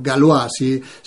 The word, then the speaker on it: Galois: Election Guard, la guardia de elecciones, es un sistema desarrollado, no desarrollado, de propiedad de Microsoft, pero desarrollado por una empresa llamada Galois o Galois, si hacemos caso a la posible pronunciación de galo en francés.